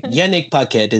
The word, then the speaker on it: Yanick Paquette: Yanick Paquette is good.